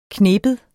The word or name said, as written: knibe